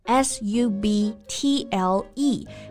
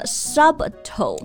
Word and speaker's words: subtle: S U B T L E 表示呢不易察觉的、微妙的，所以就不要读成了 subtle。 – Yeah. – No, it's subtle, like subtle differences，细微的差别。